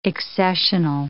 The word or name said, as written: accessional